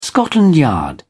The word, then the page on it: scotland yard: British English